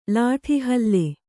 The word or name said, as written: ಲಾಠಿಹಲ್ಲೆ